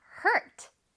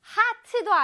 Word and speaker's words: hurt: Hurt. Hurt. 다치다, 아프다란 뜻인데요. 자, 발음을 다시 한번 해볼게요. Hurt 아니고 heart도 아니야, 얘들아. Hurt입니다. 선생님 모양 잘 따라해봐. Hurt. Hurt. 모여졌어요. 그리고 R이기 때문에 혓바닥은 뒤로 갑니다.